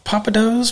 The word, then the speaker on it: Pappadeaux's: Uh, did I say it right? Pappadeaux's? Papa. – I, yes, sir.